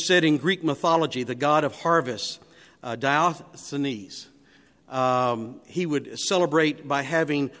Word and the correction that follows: Dionysus: I think it was pronounced Dionysus is the correct pronunciation.